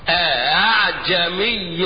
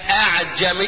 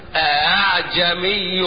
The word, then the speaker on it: أعجمي: هو حد ينطق همزتين يقول لي أعجمي؟ لا، غلط. أنطق همزة الأولى محققة والهمزة الثانية مسهلة بينها وبين الألف، لا هي همزة ولا هي ألف، وما انطقهاش خطأ. ها أقرأ هكذا، اسمع النطق كويس مني: أعجمي وعربي، أعجمي وعربي.